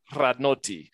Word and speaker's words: Radnoti: Radnoti. You got to roll the R. – Radnoti.